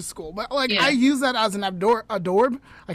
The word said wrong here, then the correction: Hors d'oeuvre: I can never say the word right. How do you say it? – Hors d'oeuvre. – Yes.